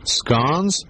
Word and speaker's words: scones: Okay, is it actually pronounced scones or scones? – Scones. – And why is that? – Because if it was pronounced scones, there would not be any E in the word.